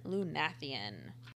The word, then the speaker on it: Lunathion: And we're calling this episode Welcome to Lunathion, which we think is how you say it. – Yes.